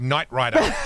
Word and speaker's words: Knight Rider: But the K is silent in Knight Rider.